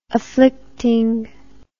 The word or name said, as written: afflicting